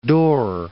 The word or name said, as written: Door Peninsula